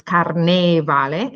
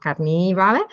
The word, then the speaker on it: carnevale: Now, if you think Italian pronunciation is difficult because we have a lot of vowel sound, like it's carnevale and not carnivale, but just try French, because they have all this masculine and feminine.